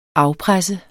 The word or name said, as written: afpresser